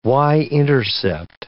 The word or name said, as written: y-intercept